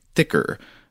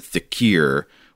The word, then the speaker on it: dhikr: so the word here is spelled D H I K R or D H I K I R. have uh, heard it pronounced thicker before, um, but uh, Sean gives a pronunciation guide in which he says to pronounce it thickir, which might be like a, a regional language variation. I'm not sure, but but since uh, since Sean gives that, I'm going to say thickir instead of thicker for the rest of the email.